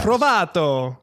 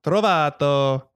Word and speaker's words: Trovato: Trovato. Joshua Trovato. – Spell it. – J-O-S-H, T as in Tom, R-O, V as in Victor, A-T-O. Trovato.